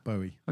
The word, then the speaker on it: Bowie: Bowie.